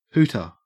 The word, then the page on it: hooter: Australian English